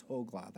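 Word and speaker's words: Hoglah: I'm doing her a favor, not saying Hogla, Hogla. She would say, no, it's Hogla. That's how you pronounce it.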